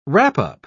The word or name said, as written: wrap-up